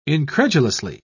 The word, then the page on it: incredulously: British English